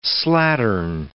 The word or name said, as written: slattern